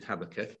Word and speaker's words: Habakkuk: We come to Habakkuk, and there's different ways of pronouncing that, but I'm going to stick with Habakkuk. I'm English.